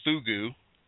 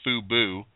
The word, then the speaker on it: Fugoo: There's a new company out called Fugu, not Fubu, Fugu. So I'll spell that phonetically. That's Foxtrot Uniform Golf Oscar Oscar, F-U-G-O-O, Fugu.